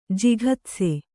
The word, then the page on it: ಜಿಘತ್ಸು: jighatsu